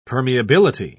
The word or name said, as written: permeability